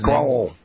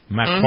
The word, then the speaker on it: Macron: I don't usually watch these press conferences, but I happen to be in a situation where I watch some of Trump and Macron together. Macron, I guess, is that how you pronounce Macron. – Macron. – Mm-hmm. Macron.